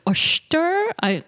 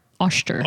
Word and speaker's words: Oshtur: So Ashtar, or Ashtar, O-S-H-T-U-R, Ashtar. – O-S-H. – T-U-R. – Ashtar. Yeah. – Ashtar. So Ashtar was an elder god who left Earth to journey the stars. Mm-hmm. On her journeys, Ashtar met Hoggoth the hoary, who was quite literally, by the way, a tiger.